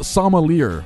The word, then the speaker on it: sommelier: Sommelier. – It's spelled sommelier. – I know. It's French, though. It's pronounced sommelier.